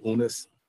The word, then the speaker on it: Unas: And one of the things you showed, you're visiting the pyramid of Unas. I think you pronounce it Unas, the king, and it's home of the people early... should look and tell people to Google that word, U-N-A-S.